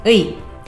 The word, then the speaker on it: 의: 의. – Que en este caso se pronuncia como e. – Como vimos anteriormente, tiene dos tipos de pronunciaciones.